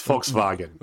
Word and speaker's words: Volkswagen: You said it. You actually said it wrong. It's Volkswagen. – Volkswagen. Yes. Guten Tag. – V is pronounced like an F.